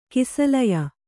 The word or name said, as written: ಕೌಸಲೇಯ